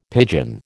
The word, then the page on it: pidgin: American English